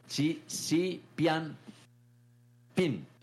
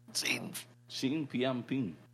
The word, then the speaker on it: Xi Jinping: En este momento se están dando la mano Xi Jinping de China. Y ¿cómo es Xi Jinping? – Xi Jinping. – A ver. – Xi Jinping.